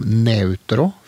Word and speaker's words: neutro: Kanskje nærmest det kommer italiensk, som vil si noe sånt som neutro for nøytrum, eller nøytral.